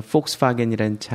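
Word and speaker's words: Volkswagen: 왜냐면 독일에서는 F 발음을 V자를 F로 읽거든. 그래서 폭스바겐이라는 차 알죠? V하고 W자 써져 있는 거.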